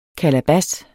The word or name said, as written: kalabas